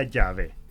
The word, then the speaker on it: Yahvé: Que los judíos pronunciaban originalmente como Yahvé, terminando con una H al final. Y que nosotros en castellano se escribe y se traduce como simplemente Yahvé, sin la H al final.